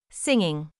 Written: singing

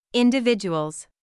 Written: individuals

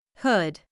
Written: hood

